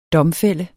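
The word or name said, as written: DOMFÆLD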